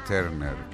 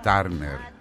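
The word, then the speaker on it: Turner: αυτή η καταπληκτική φυσιογνωμία, Τίνα Τέρνερ ή Τάρνερ. Κάπου ανάμεσα είναι η σωστή προφορά, Τέρνερ, κάπου εκεί.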